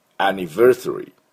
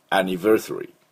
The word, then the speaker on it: anniversary: Anniversary。 Anniversary。この Versary のところに、 Ver にアクセントがあります。では私の後でいきましょう。 Anniversary。